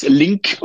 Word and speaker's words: Lingg: Mein Name ist Link und nicht Ling. Ich bin kein Asiate. Vielen Dank. Dann spricht man das Doppel-G als K aus.